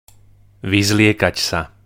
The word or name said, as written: vyzliekat sa